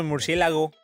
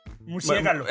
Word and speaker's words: murciélago: Es como el murciélago. – Murciégalo. – Bueno, murciégalo. Es que bueno. A veces lo dije bien ahora sí, pero sí, ya sí. Te lo toman como bien de las dos maneras.